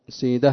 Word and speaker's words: سيده: ونقل الزبيدي في تاج العروس نسبتها للخليل عن الحريري، بينما نسبها في حضض لابن سيده في المحكم، سيده هكذا ينطق بكسر السين.